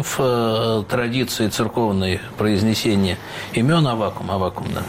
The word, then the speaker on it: Аввакум: Как все-таки правильно произносить? Я понимаю, что две нормы – Аввакум, как сейчас многие говорят, или Аввакум? – Древнерусская традиция – Аввакум.